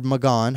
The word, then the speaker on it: McGahn: Don McGahn, okay, or McGahn, however you like to say it, sometimes the H is silent, sometimes it's not.